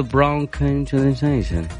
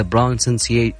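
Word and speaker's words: pronunciation: محمد مشرعي يقول براون كونجنيشن اوكي طبعا الكلمه حقت محمد مشرعي هي برونسيشن برونسيشن برونسيشن يس يس الحين جبتها صح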